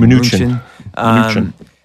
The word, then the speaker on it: Mnuchin: Mnuchin.